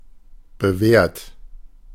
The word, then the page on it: reliable: American English; [rɪ.ˈlɑɪ.ə.bəl]